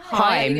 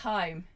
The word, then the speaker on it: Haim: I never know how to pronounce her name. Is it Esther Haim? – Haim. – It's Haim.